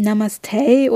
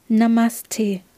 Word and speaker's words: Namaste: Also es gibt kein Namaste oder sowas oder kein Namaste. Es ist kein, kein T oder kein Stay drin, sondern es ist einfach Namaste. Die Betonung liegt auch eher auf der zweiten Silbe.